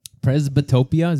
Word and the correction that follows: presbyopia: Is that what you called it? – Presbyopia. – Presbyopia.